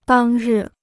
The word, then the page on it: 当日: dàng rì